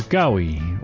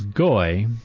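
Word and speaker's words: gawi (גוי): A word today is gawi, or often but generally mispronounced as goy, translated especially in the Old Testament as ethnos, or the Gentiles, as it came to be in the New Testament.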